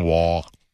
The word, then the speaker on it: Waugh: Keith, I don't know, because it's W-A-U-G-H, Waugh.